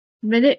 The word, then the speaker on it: minute: Particulars under this head will be more properly explained when we descend to the minute view of the enumeration which is made in the proposed Constitution. – Minute. So sometimes the minute is pronounced minute.